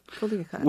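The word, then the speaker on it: Culiacán: The restaurant is called El Sinaloense, and it specializes in the food of, I'm going to mispronounce this name, Culiacan. – Culiacan.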